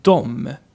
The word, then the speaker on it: de: De is how you write it, and mostly you pronounce that dom.